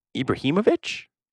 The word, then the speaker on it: Ibrahimovic: But I mean, Ibrahimovic.